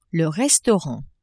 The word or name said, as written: restaurant